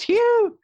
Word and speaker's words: Hugh: The actors in this film include I said it wrong last week. Hugh Jackman. – No, it's not Hugh. It's Hugh Jackman. You did it all wrong. – It's Hugh. Hugh Jackman, P.T.